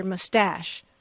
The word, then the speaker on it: mustache: It's much more common to hear mustache with the emphasis on the second syllable.